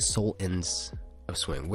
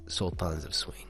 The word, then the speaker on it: Sultans of Swing: In the song, they're saying soul ends of swing. Where the soul ends of swing. But I like, you know, sultans of swing. That's how they say it. That's how you gotta say it.